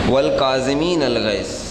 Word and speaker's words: والکاظمین الغیظ: کاظمین الغیظ، درود بھیجے گا محمد والے محمد پر، محمد والے محمد، والکاظمین الغیظ، صحیح تلفظ یہ ہے.